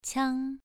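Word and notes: qiang: tone 2

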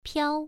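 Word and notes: piao: tone 1

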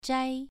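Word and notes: zhai: tone 2

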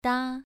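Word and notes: da: tone 2

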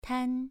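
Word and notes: tan: tone 2

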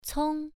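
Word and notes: cong: tone 1

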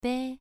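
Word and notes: bei: tone 2

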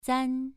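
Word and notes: zan: tone 2

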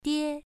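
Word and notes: die: tone 1

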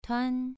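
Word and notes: tuan: tone 2

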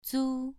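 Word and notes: zu: tone 2

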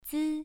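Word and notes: zi: tone 1

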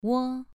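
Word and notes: wo: tone 2

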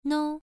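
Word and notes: nou: tone 1

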